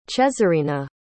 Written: chezarina

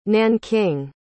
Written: nanking

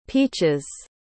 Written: peaches

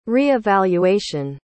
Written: re-evaluation

